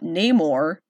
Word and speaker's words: Namor: El niño sin amor. Namor. There you go. Say it right!